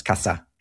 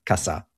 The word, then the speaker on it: casa: But in South America, where they use this ceseo, caza and casa are both pronounced the same as casa, casa.